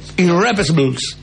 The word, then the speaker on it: Irrepressibles: ¿Se pronuncia así? ¿Irrepresibles? – Irrepressibles. – Irrepressibles. Ahí está.